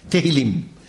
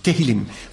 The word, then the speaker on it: Tehillim: Psalmen heißen auf Hebräisch Tehillim. Tehillim. Fangen wir mal ganz einfach an. Tehillim. Können wir es alle mal aussprechen? Ich möchte Sie gerne mit einbeziehen. Tehillim. Tehillim. Noch einmal. Tehillim.